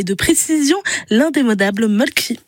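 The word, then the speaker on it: Mölkky: Inventé en Finlande en 1996, ce jeu de quilles s'écrit M-O-T-R-E-M-A-L-K-K-Y. – Ça s'écrit Mulky, mais ça se prononce Mulky. – Le Mulky. – Plutôt un E qu'un E, mais pas un U. Donc ça fait Mulky.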